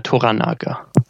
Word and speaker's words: Toranaga: Wie spricht man ihn aus? – Toranaga.